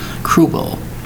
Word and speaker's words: cruel: It's, might say to yourself, sounds like one syllable. It's not. It's actually two syllables. Cruel. C-R-U-E-L.